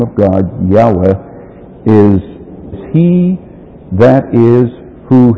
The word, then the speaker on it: Yahweh: The word Lord, you'll see that it's all capital letters in your Bible, is that Hebrew word Yehovah, and we pronounce it Yahweh in English. That's the way it should be pronounced. Generally, it is pronounced as Jehovah, and that's all right too.